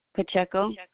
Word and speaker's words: Pacheco: L-I-Z-A. Pacheco. – Could you spell that for me? – P-A-C-H-E-C-O.